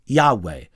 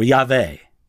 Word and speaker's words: Yahweh: the sacred four letters, or tetragrammaton, YHWH, which we would pronounce Yahweh or Yahweh.